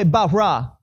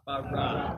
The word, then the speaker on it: bara: Everyone say bara. – Bara. – You guys say it like you're Hebrew, man.